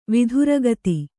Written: ವೇತ್ರಗೀತ

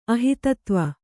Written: ಅಹಿತತ್ವ

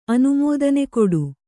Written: ಅನುಮೋದನೆಕೊಡು

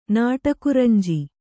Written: ನಾಟಕುರಂಜಿ

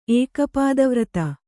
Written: ಏಕಪಾದವ್ರತ

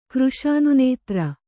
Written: ಕೃಶಾನುನೇತ್ರ

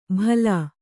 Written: ಭಲಾ

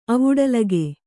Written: ಅವುಡಲಿಕೆ

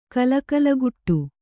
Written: ಕಲಕಲಗುಟ್ಟು

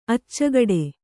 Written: ಅಚ್ಚಿಕೊಡು

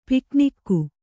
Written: ಪಿಕ್ನಿಕ್ಕು

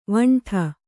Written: ವಿಂಡೆ

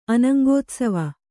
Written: ಅನಂಗೋತ್ಸವ

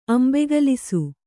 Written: ಅಂಬೆಗಲಿಸು

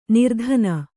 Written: ನಿರ್ಧನ

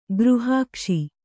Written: ಗೃಹಾಕ್ಷಿ

